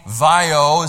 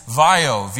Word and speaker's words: Vaio: Sony Vaio is how that's pronounced. So if you were ever wondering how to pronounce that when you've seen that or even what that says, it's Vaio, V-A-I-O.